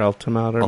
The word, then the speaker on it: altimeter: And they've all got the altimeters. – Is that how you say it? Altimeter? Altimeter? – Altimeter, yeah.